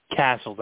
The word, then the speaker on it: Casscles: Casscles. That's how it's pronounced. Yep. Mm-hmm. – Yeah, okay. I was thinking so. It was sort of, I wasn't sure if it was a soft C or hard C.